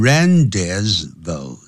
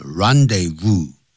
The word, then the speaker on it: rendezvous: 这个字不能念成 rendezvous 那不是英文不是英文是 rendezvous 哎